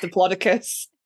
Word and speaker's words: Diplodocus: And he was like, it's Diplodocus. And I was like, I love you, but it's not. Okay. It's actually pronounced Diplodocus.